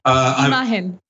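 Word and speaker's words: Imagen: Imahen. It's super easy. Imahen. Like you're overthinking. Imahen. That's it.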